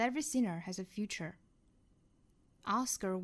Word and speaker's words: Oscar: 比如说我们看第一个单词 Oscar， Oscar，它的尾部是小刷音不要读成了是 Oscar， Oscar。